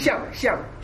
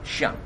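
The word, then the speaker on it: Xiang (象): And the Chinese pronunciation? – Xiang. – Xiang. – Yeah, Xiang. – And number four? – Xi Ci, Xi is high something. Tai, T-I-E, Tai something.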